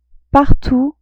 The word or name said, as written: partout